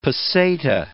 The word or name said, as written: peseta